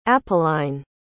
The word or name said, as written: apolline